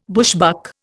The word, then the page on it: bushbuck: Indian